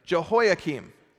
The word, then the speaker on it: Jehoiakim: However, after Josiah, Jehoiakim, say it together, Jehoiakim. There we go. That's absolutely how you say it, or it's absolutely how I'm saying it today.